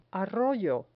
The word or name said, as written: arroyo